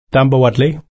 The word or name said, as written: tambawodli